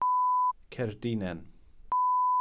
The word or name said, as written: cerdinen